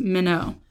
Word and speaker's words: meno: So the abide in the Greek is meno, M-E-N-O, long O. I know I just transliterated that, but either way, meno.